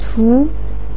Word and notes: cu2: pinyin

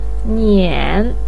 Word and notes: nian3: pinyin

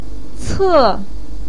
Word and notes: ce4: pinyin

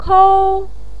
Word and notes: kou1: pinyin

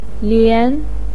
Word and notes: lian2: pinyin

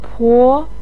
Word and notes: po2: pinyin

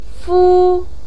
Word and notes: fu1: pinyin

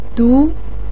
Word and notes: du2: pinyin

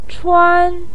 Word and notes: chuan1: pinyin

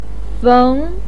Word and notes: feng2: pinyin

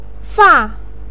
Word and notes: fa4: pinyin

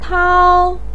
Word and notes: tao1: pinyin

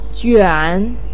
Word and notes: juan3: pinyin

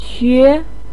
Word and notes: que2: pinyin

